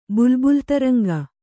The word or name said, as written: ಬುಲ್ಬುಲ್‍ತರಂಗ